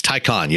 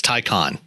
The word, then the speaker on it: Taycan: I'm saying that wrong. It's Taycan, yes. – Taycan. – Porsche says it's Taycan.